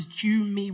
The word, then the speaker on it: Jummy: Like, because the way it's abbreviated, or it was abbreviated at that time, was Jummy with an J-U-M-M-Y. I was like, it sounds like mummy.